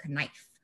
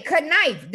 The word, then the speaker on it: knife: She was like, bitch, what the fuck is a knife? – Your mom was like, let's try again, babe. – What does that say? Knife. The K is silent.